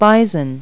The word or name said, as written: bison